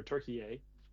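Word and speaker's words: Türkiye: and just learn a ton about Turkey or Turkey. Mm-hmm. As the proper pronunciation.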